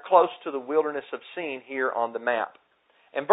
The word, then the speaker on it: Sin: we might pronounce Sin. But let's not confuse the two, because the biblical location is pronounced Sin, like in S-E-E-N.